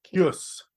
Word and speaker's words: Kyuss: K-Y-U-S-S is how you spell it. – Kyus. – Kyus. What do you say? Kyus.